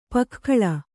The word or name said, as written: ಪೊಗೆಗೊಳ್